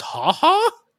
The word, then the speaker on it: Ha Ha: Ha, is it pronounced ha ha? – Yep. It is.